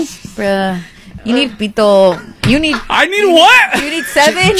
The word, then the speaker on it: pito: It's pito. – It is. Mabuhay. – No. Pito.